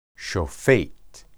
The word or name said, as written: shophet